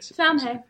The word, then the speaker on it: Samhain: And obviously, in modern times, Samhain, pronounced as you said, Samhain. – Samhain, not Samhain.